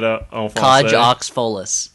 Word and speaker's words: Cage aux Folles: Cage aux Folles? Mm-hmm. Isn't that how you say it? – Cage aux Folles.